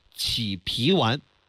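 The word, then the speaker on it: 启脾丸: 我告诉你那两个事儿啊，第一，儿童版的补中益气丸起脾丸可以给他吃。 – 哦，起脾丸是吧？ – 这起，开启的起。 – 开启的起。 – 哎，我其实正确的读音叫起脾丸，脾就是脾胃的脾。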